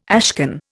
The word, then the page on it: ashcan: Indian